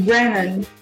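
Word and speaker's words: Branagh: é Brennan, Brennan, não sei pronunciar o nome do cara. – Isso, Brennan. Kenneth Brennan.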